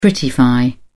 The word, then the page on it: prettify: British English